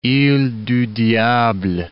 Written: ile du Diable

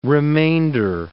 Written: remainder

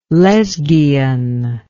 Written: Lesghian

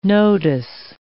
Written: nodus

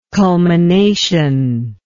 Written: culmination

